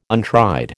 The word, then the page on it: untried: American English